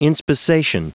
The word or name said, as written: inspissation